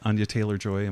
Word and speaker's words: Anya Taylor-Joy: And is it Anya Taylor-Joy? Am I saying her name right? – You are saying her name right.